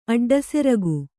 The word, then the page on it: ಅಡ್ಡಸೆರಗು: aḍḍaseragu